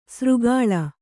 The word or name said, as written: ಸೂರೆಗೊಳ್